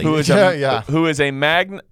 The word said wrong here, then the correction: manga: Magna? Am I saying it right? Why do I feel like I'm not? – Magna cum laude? – Magna. – Manga. – Manga. – Manga. – Manga. – Oh, yeah. Manga.